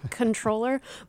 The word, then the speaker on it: comptroller: And I do just want to note that we all here at the news service are aware of the alleged correct pronunciation of comptroller being controller, but we choose not to observe it.